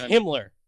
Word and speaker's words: Himmler: Hey, speaking of Hibbler, those robots were kind of doing a little goose stepping there. That was kind of cool. – Yeah. – But, it's pronounced Himmler.